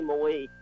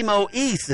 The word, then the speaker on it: Moise: Patty Moise is who I'm trying to think of. – No, Moe. Moe. – Moe? – Patty Moe. – I've been pronouncing it wrong for 40 years, 30 years.